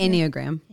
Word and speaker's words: Enneagram: How do you say Enneagram? – Enneagram.